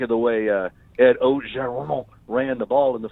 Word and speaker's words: Orgeron: I said, I've heard Orgeron. I've heard Ogeron. I said, how do you say it? And he said, well, in Cajun, it's Ogeron. – I said, Ogeron. – Ogeron. So I go Ogeron because it's the closest thing to it.